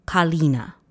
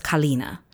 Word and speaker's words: Kalina: Oy ilusi Shervana Kalina. So it's Kalina. And I kept calling it Kalina last night when I was listening to everything.